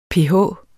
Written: pH

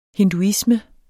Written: hinduisme

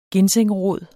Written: ginsengrod

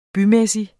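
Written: BYMÆSSIG

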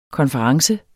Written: konference

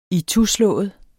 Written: ituslået

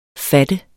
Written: fattet

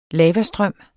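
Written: lavastrøm

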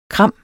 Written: kram

